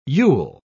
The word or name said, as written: you'll